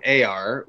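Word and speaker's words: Ēar: So this week I pulled the rune AR, which is spelled like ear, E-A-R.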